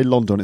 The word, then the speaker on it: London: Now, most people don't say London. It's probably a bad example. But anyway, London. So the stress is on the first syllable.